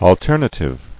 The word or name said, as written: Alternatively